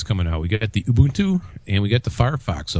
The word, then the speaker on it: Ubuntu: Yeah, so anyway, it's kind of interesting that Ubuntu, I've been saying that wrong all these years. I always said Ubuntu. It's actually Ubuntu.